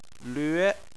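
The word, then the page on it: lve: tone 2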